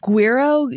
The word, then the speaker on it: Guiro: I mean, a lot of people just call them percussion frogs, but Guiro, G-U-I-R-O.